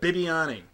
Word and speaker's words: Bibbiani: This is William Habibi. Did I say it right? – No. – How is it? – Bibbiani. – Bibbiani? – Yes.